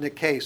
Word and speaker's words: Nike: Nike, it's actually pronounced Nike. So if you want to correct people, just tell them, those aren't Nikes, those are Nikes.